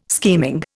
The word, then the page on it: scheming: Indian